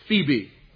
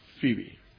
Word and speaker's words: Phoebe: Phoebe. Phoebe. I know, children, that doesn't look like Phoebe. It looks like Phoebe, but the O-E kind of merges together in the long E sound.